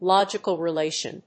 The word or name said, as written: logical relation